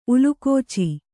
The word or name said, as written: ಉಲುಕೋಚಿ